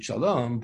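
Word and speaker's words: Shalom (שלום): The Gemara has a, Torah Shabbal Peh has a comment that you read the word Shalom, you pronounce it Shalom, but you darshan as if it were written Shalem.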